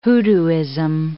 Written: hoodooism

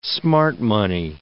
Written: smart money

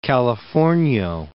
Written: Californio

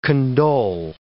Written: condole

